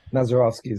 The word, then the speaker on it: Masurovsky: Mazurovsky is correct.